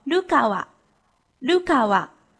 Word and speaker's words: 流川: 里面的流川枫的姓氏流川就读作 Lukawa，Lukawa，这样会不会好记一些呢？